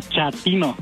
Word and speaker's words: chatino: Chapino. – ¿Chapino? – No, chatino. – Es como chato, ¿verdad? Chatino. – Sí, azunero como chato, chatino.